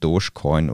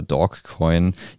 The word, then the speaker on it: Dogecoin: Am Samstag war Ian Musk zu Gast in der US-Show Saturday Night Live und hat dort im Vorfeld mehrmals Tweets über den sogenannten Dogecoin oder Dog-E-Coin oder Dogcoin, jedenfalls schreibt man es D-O-G-E, hat er mehrere Tweets drüber gepostet.